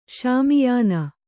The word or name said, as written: ಶಾಮಿಯಾನ